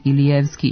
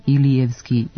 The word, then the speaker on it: Ilijevski: Zato ćemo makedonska prezimena sa završetkom ski izgovarati u skladu sa svojim navikama, a naše jezičke navike išle su ka tome da prenošenje akcenta ne bude izvorno, nego pomereno za jedan slog unazad, pa će se prečuti Ilijevski i Donevski, a ne Ilijevski i Donevski, kao u makedonskom.